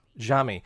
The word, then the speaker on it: Xiaomi: Xiaomi.